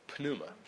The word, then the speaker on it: pneuma: No joking, the P is pronounced there, pneuma.